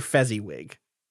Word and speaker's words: Fezziwig: I was like, wait, is it Fozziwig? – No. – Could you spell it for me? – How do you think it's spelled? – Like Fozzie the Bear. – But with an E, because Fezzi. – Oh. – It's Mr. Fezziwig. – So Fezziwig? – You got it.